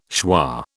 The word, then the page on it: schwa: British English